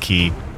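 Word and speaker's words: Cay: It's the K, but I think we're mispronouncing that. I looked it up. It's key.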